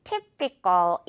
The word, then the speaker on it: typical: type이라는 단어에서 이제 시작한 거긴 하지만요. typical 이렇게 발음되죠. type 하면 어떤 전형적인 종류잖아요. 종류. 그래서 이 typical이란 단어도 전형적인이라는 뜻인데 이럴 때는 typical이 아니라 typical 이렇게 발음돼요.